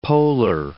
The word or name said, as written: poler